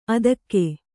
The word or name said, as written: ಆದೀಕ